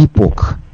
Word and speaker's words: Epoch: «Epoch».